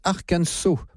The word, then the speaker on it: Arkansas: Ça s'écrit Arkansas, mais ça se prononce Arkansas. Donc, les affaires sont claires. Je dirais Arkansas tout au long de cette émission.